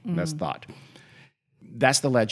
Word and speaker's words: Thoth: There's this idea of the, because there was, the, I think it was pronounced as T-H-O-T-H, and I think it's pronounced Thot, one of the ancient Egyptian gods who was a god of magic.